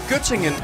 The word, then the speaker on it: Göttingen: Var det ved Gødringen? – Nej, Göttingen. – Göttingen, ja, men det er... – Göttingen ost. – Jamen, det er bare mig, der ikke kan udtale det korrekt.